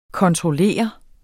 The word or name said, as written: kontroller